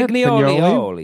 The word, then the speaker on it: pignoli: How do you say pignoli.